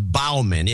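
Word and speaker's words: Baughman: It's B A U G H M A N, which should be pronounced Bauman.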